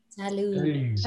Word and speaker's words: salut: Salut. – T is silent, le T il est silencieux, salut.